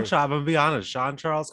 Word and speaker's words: Jean Charles: Shemar Jean Charles or John Charles pronounces his name Shemar Jean Charles.